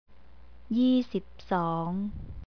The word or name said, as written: yeesipsong